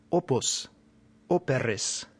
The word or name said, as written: opus